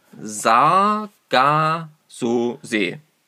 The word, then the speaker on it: Sargasso-See: Offensichtlich haben wir dieses Wort immer ein bisschen falsch ausgesprochen. Das Meer heißt Sargasso-See.